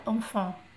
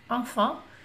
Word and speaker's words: enfant: Mas se fala enfant enfant, e se escreve EN, então quer dizer que o EN já não é, é, já não é em, né?